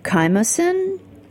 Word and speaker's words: chymosin: I do like your, it's a picture of rennet, the chymosin? – Oh, yeah. – Is that how you say it, chymosin? – Chymosin, that's right.